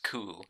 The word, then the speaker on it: coup: It says coup, and that's how it's pronounced, coup.